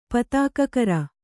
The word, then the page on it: ಪತಾಕಕರ: patākakara